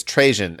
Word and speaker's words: Trajan: Trojan? Like Trojan what? Horse. – Trojan. – No. I'm lying. It's Trajan, actually.